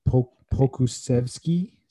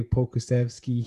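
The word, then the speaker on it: Pokuševski: And then actually this rookie here, I think you say his name, Alexei Pokusevsky, something like that. – Yeah, that sounds right to me. – Alexei Pokusevsky, he had 23 points, 10 rebounds, 4 assists, 1 steal, 1 block.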